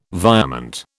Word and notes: virement: British English

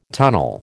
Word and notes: tunnel: American English

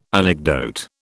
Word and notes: anecdote: British English

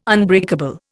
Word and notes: unbreakable: Indian

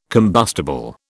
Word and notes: combustible: British English

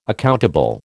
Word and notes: accountable: American English

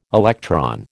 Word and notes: electron: American English

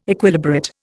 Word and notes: Equilibrate: Indian

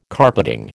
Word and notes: carpeting: American English

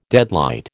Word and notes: deadlight: American English

